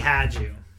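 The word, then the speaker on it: Hajdu: Her last name had some like... like a silent J or something. Or a silent something. – It is Pat. – I know it's Pat. – Damn it. It's Patty Hadju. – Had you, there you go. Had, yeah, see, silent J. She had you.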